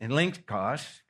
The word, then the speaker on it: elenchos: The second term here is elenchos. That's how you say it, elenchos, because it's difficult.